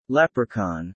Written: leprechaun

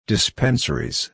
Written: dispensaries

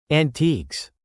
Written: antiques

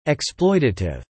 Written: exploitative

